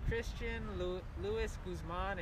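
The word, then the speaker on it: Christian Louis Guzman: My name is Christian Luis Guzman. It's C-H-R-I-S-T-I-A-N-L-O-U-I-S-G-U-Z-M-A-N.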